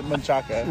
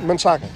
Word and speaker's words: Manchaca: The correct pronunciation is Manchaca. – Manchaca, yeah. – Manchaca. Well, I'm down with that, yeah. – It ends in an A.